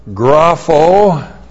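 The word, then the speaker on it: Grapho (γράφω): And then we have grapho. G-R-A-P-H, long O. Grapho.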